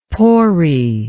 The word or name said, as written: Pori